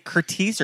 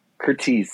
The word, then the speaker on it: Curtiz: Is it Curtiz or Curtis? – Curtiz. – Yeah. Curtiz. I feel like everything Curtiz. That I've read and have seen interviews, they pronounce it Curtiz.